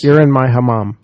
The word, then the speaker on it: hammam: They have something that what is known as the hammam, or did I say that right? – Hammam. You did.